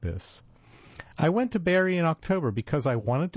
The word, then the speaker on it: Barre: Barry, spelled B-A-R-R-E, but pronounced Barry, is a small city in the bowl of a steep valley in the northern reaches of a lightly populated mountainous state.